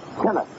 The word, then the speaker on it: Tenace: I had no idea about that at all, said Tennis, who pronounces his name Tennis, as in T-E-N-N-I-S, and who celebrated his 26th birthday only 4 days ago.